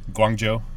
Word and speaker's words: Guangzhou: It's Guangzhou, is it? – Guangzhou. – Yes, that one.